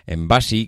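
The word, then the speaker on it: Embassy: Esta empresa, de nombre Embasi, que yo creo es como pronuncian muchos madrileños, Embasi, que es el, yo creo que el nombre en inglés, pues es una histórica, es una histórica y cuando además decimos en este caso histórica es, bueno, pero con todas las letras.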